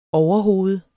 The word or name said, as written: overhoved